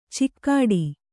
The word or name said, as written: ಚಿಕ್ಕಾಡಿ